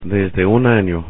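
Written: desde un año

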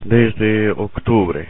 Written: desde octubre

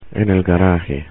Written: en garaje

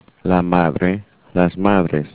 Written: madre

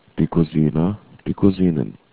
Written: Kusine

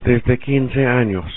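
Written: desde 15 años

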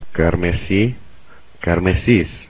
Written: carmesí

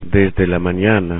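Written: desde la mañana

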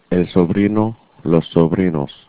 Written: sobrino

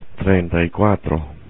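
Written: treinta y cuatro

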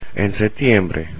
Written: en setiembre

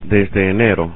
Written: desde enero

